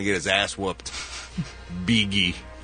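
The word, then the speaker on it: Bigi: Not Biggie. – Oh. – Beegee. – Beegee. – Beegee. – That's, okay. – Beegee is a German name that means idealistic, sensitive, and inspirational. Pronounced Beegee.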